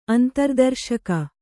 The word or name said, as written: ಅಂತರ್ದರ್ಶಕ